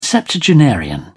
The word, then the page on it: septuagenarian: British English